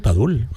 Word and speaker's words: Padul: Tengo también Padul. Padul, sí, no sé si lo digo bien, perdonadme. – Sí, Padul, Granada.